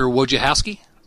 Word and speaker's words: Wojciechowski: Asher Wojciechowski.